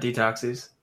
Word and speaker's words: Detoxys: Detoxys.